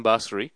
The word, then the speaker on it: Basseri: It is Ryan Basseri.